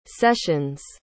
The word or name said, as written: sessions